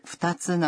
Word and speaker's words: 二つ: 上次我们学过数字的 2 念作に，为什么在这儿是二つ呢？